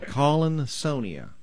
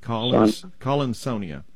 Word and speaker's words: Collinsonia: It's C-O-L-L-I-N, and then S-O-N-I-A, almost like the word Sonia. Collinsonia. – Okay. – At least that's the way I pronounce it.